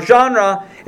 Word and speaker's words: genre: Now, genre, if you don't know, is spelled G-E-N-R-E. That was one of those words that I heard pronounced, and I read, and it took me a while to realize that they were the same, because it doesn't look anything like how it's pronounced.